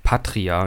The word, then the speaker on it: Patria: Nee, ich hab's falsch aufgesprochen vorhin. Es heißt einfach Patria und ich habe das T und das R, glaube ich, vertauscht.